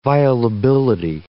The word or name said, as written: violability